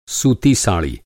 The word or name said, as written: साड़ी